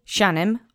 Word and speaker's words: sianem: Sianem, object form. Let's say sianem together three times. Accent is on the first syllable.